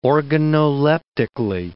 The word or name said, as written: organoleptically